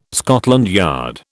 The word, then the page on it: Scotland Yard: British English